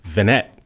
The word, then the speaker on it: Vennett: Please welcome Vermont favorite, Nate Vennett. I said that right, huh? – You did, Adam. – Excellent. I always thought it was Vennett, but it's Vennett. – It's Vennett, shortened from Vinetsky. It's Polish originally.